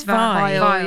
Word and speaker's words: Veil: So Simone, I'm going to pronounce her name, Veil. Simone Veil. – I think it's Vile.